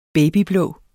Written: babyblå

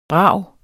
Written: brag

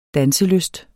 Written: danselyst

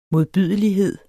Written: modbydelighed

